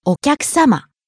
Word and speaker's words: お客様: お客様。 – Guest. Our customers. Sir. Madam. – お客様。お客様。 – Starting low, rising on the second syllable きゃ。And falling on the last syllable, ma.